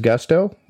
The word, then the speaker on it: Gusto: What is Gusto? Well, it rhymes with musto or rusto, as in let's do it once more with Gusto.